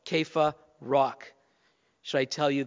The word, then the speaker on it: Kepha: The nickname that he was given by Jesus in Aramaic, which was the common language the people were speaking, was Kepha. In English, you'll see it spelled C-E-P-H-A, so some people want to say Sepha or Sepha, but it's pronounced Kepha, and it means rock.